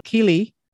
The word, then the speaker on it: Keighley: So you guys are from, oh, I hope I pronounce this right, Keighley.